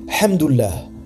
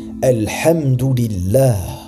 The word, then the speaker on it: Alhamdulillah: D'autres disent donc Hamdullah, qui signifie louange d'Allah, ou Alhamdullah, qui encore une fois est une erreur. La seule prononciation correcte est Alhamdulillah.